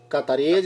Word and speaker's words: cataríes: El invitado que tuvimos ahí con, los catarines, o cómo se dice cataríes, cataríes yo creo, güey.